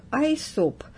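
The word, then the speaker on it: Аэсоп (Αἴσωπος): На греческом его имя произносится как Аэсоп и обозначает «око».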